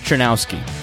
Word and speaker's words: Czerniawski: I'm very excited to talk today to James Chernowski, who gave me a pronunciation guide before.